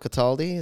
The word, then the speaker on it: Cataldi: Well, I'm sitting here with Emiliano Cataldi.